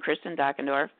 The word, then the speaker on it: Kristin Dockendorff: It's just my name, Kristin Dockendorff, on Facebook.